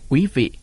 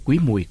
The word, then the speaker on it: Quý Mùi: Cụ Trần Trọng Kim chép là ngày mùng 7 tháng 10 năm Quý Mùi 1883, đọc là Quý Vị hay Quý Mùi cũng được.